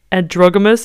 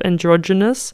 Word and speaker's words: androgynous: Also, before I continue, I did get a lot of shit last episode for saying androgamous instead of androgynous. So thank you for everyone that corrected my pronunciation.